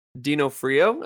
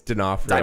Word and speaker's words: D'Onofrio: I know a lot of people are looking forward to 18 episodes featuring Vincent D'Onofrio. Is that how to pronounce it? – Oh, sorry, it's D'Onofrio.